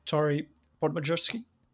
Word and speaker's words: Torrey Podmajersky: And well, I'm very excited to say that today we have Tori Podmajerski.